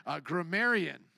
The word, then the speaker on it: grammarian: So I added a T, no grammatarian there. Grammarian. There we go.